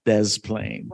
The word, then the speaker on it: Des Plaines: Does that go on where you would say to the reporter, no, sorry, you need to say this right. It's Des Plaines.